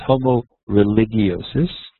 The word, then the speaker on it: Homo Religiosus: In Latin, there's no hard G. I learned from years of high school Latin. So it's Homo Religiosus, or Religious Man.